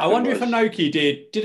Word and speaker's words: Inoki: So Bret Hart was trained by, pronounce it again for me, Inoki? – Inoki.